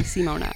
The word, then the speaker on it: Simona: They just wouldn't pronounce the I. Like, you can say it in Fiona. You can say Maria. How come you can't say Simona?